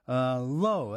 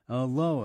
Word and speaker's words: Lau: And then we heard a young Scottish group, Low, that's spelled L-A-U, doing Ghosts, and then followed by a tune called It's Hard to Seem to Be Okay When You Are Not. That's Low,